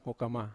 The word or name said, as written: hokama